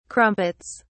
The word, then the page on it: crumpets: British English